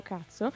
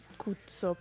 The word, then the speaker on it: Kutso: si chiamano Cazzo, però scritto K-U-T-S-O, quindi non proprio quello che si intende, ma vabbè, la pronuncia poi è quella, se volete li chiamo Cuzzo, però...